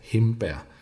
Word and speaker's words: hindbær: Et hindbær, det udtaler vi oftest med en M-lyd. Altså himbær.